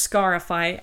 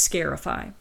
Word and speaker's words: scarify: And it could be pronounced scarify, as in to make a scar, or sometimes scarify.